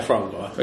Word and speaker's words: foie gras: In Paris, I had foie gras. How do you say that? – Yeah, foie gras.